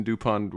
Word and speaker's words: Dupont: Yeah, so my name is Christian Dupont with a K. So KristianDupont. – And the T is silent.